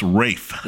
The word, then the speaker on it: Ralph: The clip I sent you, they're actually interviewing him, and he gives a little funny story about how the British spell that name like Ralph, but they pronounce it Rafe.